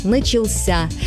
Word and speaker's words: начался: Посмотрите, в неопределенной форме мы говорим «начаться», ударение падает на второй слог, а вот в форме прошедшего времени мужского рода ударение уходит в конец, «начался».